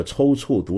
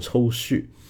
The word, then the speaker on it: 抽搐: 比如说我把这个抽搐读成了抽蓄，那么很多朋友非常正确的指出来了，感谢你们，感谢你们，你们说的对。